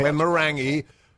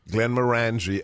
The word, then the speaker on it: Glenmorangie: It's a little bit too peaty, I think, for Boris, but my favorite single malt is, of course, the Glenmorangie. Not Glenmorangie. Glenmorangie, and in certain bars in Scotland, if you pronounce it right, you'll get a double shot for the price of one. Check the cask strength version and also the port cask.